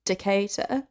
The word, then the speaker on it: Decatur: So it's not stupid. It's pronounced Decatur, mm-hmm.